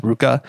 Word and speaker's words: RVCA: But it's, so if you don't know what Ruka is, it's RVCA, but it's actually pronounced Ruka.